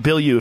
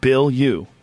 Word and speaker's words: Bilyeu: Tom Bill You, if you're wondering, Bill you. Bill you. People are wondering, like, how do I say that? – that? Bill you.